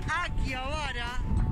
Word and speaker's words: Akihabara: Escuchamos, Noche en Akihabara, aquí como se debe pronunciar.